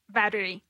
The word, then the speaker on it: battery: Some people will only say batteries. They'll miss the E in the middle. So say it honestly. How would you say it honestly without thinking about it? Or can you? – Battery. – Okay, so you do say it with an E.